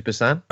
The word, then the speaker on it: Busan: It's Train to Busan.